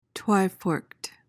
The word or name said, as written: twiforked